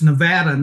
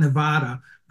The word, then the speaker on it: Nevada: Well, I am obliged contractually to tell you that it's Nevada, not Nevada, and I'm the gatekeeper of the pronunciation here.